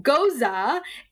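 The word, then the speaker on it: Gose: It says Ghost, pronounced Goza.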